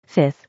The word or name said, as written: 5th